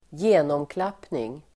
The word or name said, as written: genomklappning